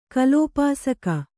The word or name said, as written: ಕಲೋಪಾಸಕ